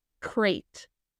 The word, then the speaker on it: Crait: Crate. – Spell that for me. Like C-R-A-T-E? – C-R-A-I-T.